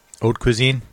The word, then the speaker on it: haute cuisine: So I've been saying haute. I'm wrong. I don't like the haute. I like the H. I like haute. Haute cuisine.